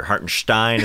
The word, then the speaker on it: Hartenstein: Hartenstein is how you pronounce it, but hey, that's fine.